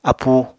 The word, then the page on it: apúu: Apatani